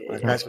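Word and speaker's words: Guice: Darius Geis, ja. Geis wird ausgesprochen.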